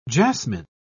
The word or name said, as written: jasmin